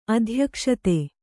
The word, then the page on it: ಅಧ್ಯಕ್ಷತೆ: adhyakṣate